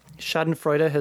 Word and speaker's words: Schadenfreude: Something like Schadenfreude. – Yes, that's close enough pronunciation. Um, Schadenfreude is is how I've always said it.